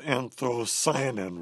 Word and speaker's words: anthocyanin: I was going to ask you if I pronounced anthocyanin right.